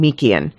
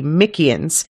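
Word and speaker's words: Myykian: He's been identified as a, and I'm choosing a pronunciation here because we've only seen it written down, but it's M-Y-Y-K-I-A-N. So I'm saying Meekian.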